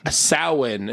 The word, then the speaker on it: Samhain: Samhain is really how it's pronounced, I believe.